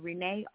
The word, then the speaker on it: Renee: My name is spelled D-O-N-Y-A-L-E, Danielle, and Renee, R-E-N-E-E.